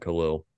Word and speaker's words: Khalil: So just like we talked about Khalil, sorry, he pronounces his name Khalil.